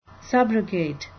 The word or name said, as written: subrogate